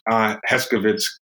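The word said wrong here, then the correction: Herskovits: kind of made a very good case for showing that there is a lot of African culture. Herskovitz.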